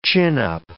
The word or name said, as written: chin-up